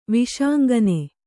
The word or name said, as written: ವಿಷಾಂಗನೆ